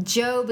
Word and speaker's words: Job: And the instructor said that it looks like job, but it actually is pronounced Job.